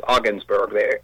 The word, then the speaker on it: Ogdensburg: Ogdensburg is pronounced Ogdensburg. They take away the D. So hey, there you go. – Interestingly, that actually makes my point more correct. They make the D silent, which is unusual because a D is not usually silent in that way.